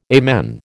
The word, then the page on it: amen: American English